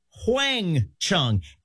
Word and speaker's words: Huang Chung: Now, we spell Wang Chung W-A-N-G-C-H-U-N-G. That's not the original spelling of the band, though. They actually first spelled their name Huang Chung, H-U-A-N-G, with the H being silent. But then people started pronouncing the H, and it just got very confusing.